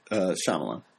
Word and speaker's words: Shyamalan: Tom Smale, in his review of The Cabinet of Dr. Caligari, has written, Shyamalan, How does M. Night pronounce his last name? – Shyamalan. – Shyamalan.